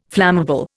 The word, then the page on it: flammable: Indian